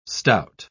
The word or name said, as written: stout